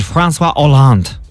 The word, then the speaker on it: François Hollande: And the former French president, François Hollande, is 72.